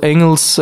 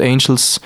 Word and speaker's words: Engels: Engels, wie man es sagt, und Engels tut man es dann aussprechen, genau.